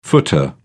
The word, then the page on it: footer: British English